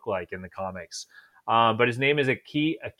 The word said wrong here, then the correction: Akihiko: I'm sorry, anyone that speaks Japanese or is Japanese, probably totally butchering this name, but it's A-K-I-H-I-K-O. – Akihiko. Okay.